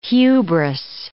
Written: hubris

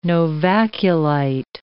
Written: novaculite

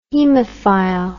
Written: haemophile

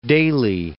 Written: Daley

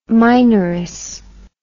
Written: minoress

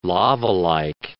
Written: lavalike

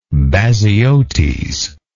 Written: Baziotes

